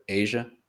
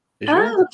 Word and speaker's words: Azure: As far as I'm aware, anyway, it's like everyone in my current team calling Azure Asia, Asia, like you connected. Okay. I was like, what, Asia? Yeah, Asia. And I was like, Azure? – Okay, that's also different.